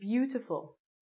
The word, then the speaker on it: beautiful: Because this word, all of these vowels together make one sound, it sounds like you. Beautiful.